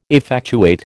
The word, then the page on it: effectuate: American English